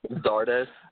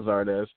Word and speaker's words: Zardes: Zardes. – Yeah, there we go, Zardes.